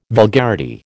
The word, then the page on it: vulgarity: American English